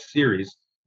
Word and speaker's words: Ceres: And Ceres, the dwarf planet Ceres, pronounced Ceres like television series, but it's spelled C-E-R-E-S, for anybody who doesn't know, is in between Mars and the asteroid belt.